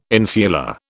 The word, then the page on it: infula: British English